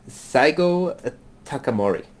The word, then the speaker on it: Saigo Takamori: I'm going to pronounce the name terribly. Saigo Takamori. – Okay. – Oh, that was actually pretty good.